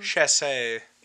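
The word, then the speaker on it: Chasez: Chassez.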